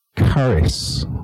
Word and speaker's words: charis: The word for grace is the word charis, okay? It's meant to be pronounced with a kind of a CH, a hard CH, like the word loch in Scottish.